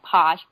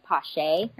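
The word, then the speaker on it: Paasche: It looks like Posh, but it's actually like Poche or something like that.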